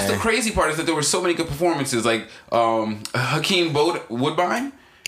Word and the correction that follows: Bokeem Woodbine: Bokeem Woodbine.